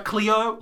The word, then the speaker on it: Cleo: So, like, Cleo, like, the magazine. Does anyone else remember that was a magazine? – No. – Cleo. Like, the first syllable in... No, first two syllables in Cleopatra.